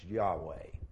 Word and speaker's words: Yahweh: And he used this word that we think is pronounced Yahweh.